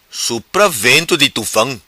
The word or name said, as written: Supra Veno Di Tufao